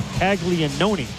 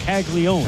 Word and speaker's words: Caglianone: It's spelled C-A-G-L-I-A-N-O-N-E, like Caglianoni, but it's just Caglione.